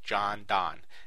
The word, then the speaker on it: Jondon: One way to do that is by looking into the seminars provided by a company called John Don. It's spelled J-O-N-D-O-N.